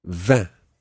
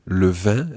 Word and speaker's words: vin: Le vin is wine. Le vin. – Le vin. – It sounds exactly the same as the word for 20. Vin. – Vin. – But it's spelt differently. It's V-I-N.